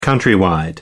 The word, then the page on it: countrywide: American English